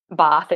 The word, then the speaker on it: Bath: And he wasn't even supposed to be in Bath that night, Bath, if you say it correctly.